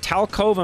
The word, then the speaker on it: Talcove: Is it Talcove? Am I saying that right, Haywood? – Yes, sir. You got it.